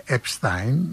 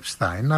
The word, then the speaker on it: Epstein: Εδώ στην Ελλάδα λένε Epstein, γιατί κανονικά ως γερμανικής προέλευσης προφέρεται Epstein, αλλά στις Ηνωμένες Πολιτείες της Αμερικής, που τα προφέρουν εξαμερικανισμένα, προφέρεται Epstein. Και εγώ βεβαίως, εφόσον ο ίδιος προσφωνούσε τον εαυτό του Epstein, χρησιμοποιώ το Epstein και όχι το Epstein.